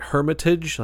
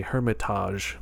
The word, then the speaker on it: Hermitage: It's spelled like Hermitage, like T-A-G-E, but they say Hermitage because, you know, they're Portuguese and it's fancy.